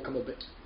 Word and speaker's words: Cumberbatch: It's Blumber Black Cumberbatch. – Yeah. Can't be Arch. – You can spell his name, you can say his name however you want. It's still Cumberbatch. Benedict Cumberbatch.